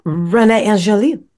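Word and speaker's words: Angélil: And did you know that Celine and her manager, Renee, how do you say it? Angelil? – Sounds right. – Yeah. Renee Angelil.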